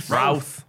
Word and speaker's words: Routh: Chris Ruth. – Routh. Oh, Rhymes with South. – Chris Routh of the South.